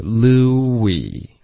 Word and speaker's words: Louis: 所以他这个拼写的方式呢，跟英文的这个名字 Louis 啊是一模一样的，可是他的发音就有区别，因为他是法语，所以他的发音就是 Louis。 – Louis，对，it makes no sense，完全不合理。 OK，有一个 S 的音，这个 S 不发，我们发 Louis。